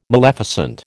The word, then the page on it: maleficent: American English